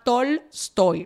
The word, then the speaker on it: Tolstoy: Se llama Tolstoy, lo estoy pronunciando literalmente, T-O-L-S-T-O. Y.